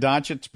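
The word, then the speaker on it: Dončić: Yeah, speaking of Doncic, I'm pronouncing it Doncic because I've looked at it as far as like searching the web and trying to figure out exactly how to say this because everybody's pronouncing his name differently.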